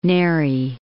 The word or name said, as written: Neri